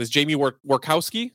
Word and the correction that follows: Tworkowski: Is that right? – Tworkowski. – Tworkowski. Oh, it is.